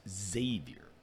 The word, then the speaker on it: Xavier: Right, that's not how you pronounce it. – That's right. It's Xavier, as we know.